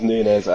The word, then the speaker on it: Nunes: Can we get a Portuguese pronunciation on those? – It is Nunes and it's Gedge, I think.